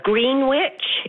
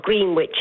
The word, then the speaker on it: Greenwich: So, and another one is Greenwich. It's not Greenwich, it's Greenwich.